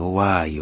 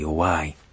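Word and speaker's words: ouai (οὐαί): La parola qui per guai è il termine greco ouai. La pronuncia stessa di questa parola è un lamento, ouai, ouai.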